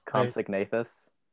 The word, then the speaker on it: Compsognathus: Compsognathus. – Comp Compsognathus.